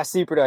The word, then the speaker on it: Sieperda: Yeah, Sieperda.